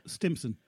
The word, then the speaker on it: Stimpson: Tim Simpson. Oh, I always get his name wrong. – Stimpson. – That's it. Tim Stimpson. There always seems like too many T's in his name.